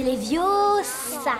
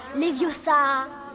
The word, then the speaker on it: Leviosa: C'est Leviosa. Et pas Leviosa.